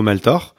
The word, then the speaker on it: Maltor: Maltor, comme ça se prononce, M-A-L-T-O-R.